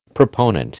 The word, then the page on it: proponent: American English